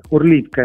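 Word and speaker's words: Horlivka: in questa città che si chiama Gorlovka in russo e Orlivka è la pronuncia ucraina,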